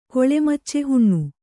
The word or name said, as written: ಕೊಳೆಮಚ್ಚೆಹುಣ್ಣು